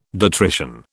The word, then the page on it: detrition: British English